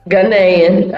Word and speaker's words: Ghanaian: Ghanaian.